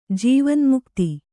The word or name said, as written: ಜೀವನ್ಮುಕ್ತಿ